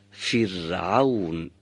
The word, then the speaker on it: فرعون: و اگر قبل از اون، یعنی قبل از را ساکن، کسره باشه، باید را سکوندار رو نازک تلاوت کنیم و تلفظ کنیم، فرعون.